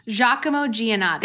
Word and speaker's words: Giacomo Giannotti: But so this episode marks the first appearance of, I don't know how to say his name. – Giacomo Giannotti. – Thank you.